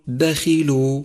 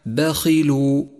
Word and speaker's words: بخيل: خامسا، لاحظ حرف الخاء المكسور في كلمة بخيل بخيل، نعم هو حرف مفخم دائما، ولكن تفخيمه هنا بأدنى المراتب لأنه مكسور، فالصواب أن تقول بخيل، والخطأ الشائع بخيل.